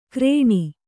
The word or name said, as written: ಕ್ರೇಣಿ